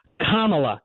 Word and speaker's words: Kamala: And I remember, I think Stephen Colbert was, it's Kamala, Kamala, like Kamala, get it in your head.